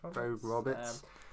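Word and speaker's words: Vogt-Roberts: Is it Vogt Roberts? I don't want to mispronounce it. – Vogt Roberts.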